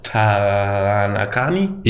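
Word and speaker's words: Taranaki: Det er nemlig Mount Taranakani.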